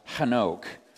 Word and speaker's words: Hanoch: And they named their firstborn child Hanoch, which is just the Hebrew pronunciation of what we say Enoch, right?